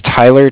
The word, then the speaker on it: Tyler: My name is Tyler, T-Y-L-E-R. And I'm calling with Cardiff. It's spelled C-A-R-D-I-F-F, as in Frank.